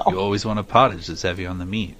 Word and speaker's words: pottage: As I said, apparently the first records of plum pudding dates to the early 15th century when plum potage, how do you say P-O-T-T-A-G-E? – Yeah, that's right. I would say pottage.